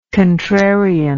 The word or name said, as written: contrarian